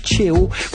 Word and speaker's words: Cheo: Je vous propose un artiste qui s'appelle Cheo. Vous l'avez peut-être connu comme membre fondateur de l'excellent groupe funky vénézuélien Los Amigos Invisibles. Et je regarde Alejandro qui me fait un oui, j'ai prononcé correctement.